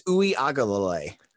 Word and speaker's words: Uiagalelei: So I found the pronunciation guide so I didn't sound like a complete caveman trying to botch his name up. It's Uiagalelei. – Uiagalelei.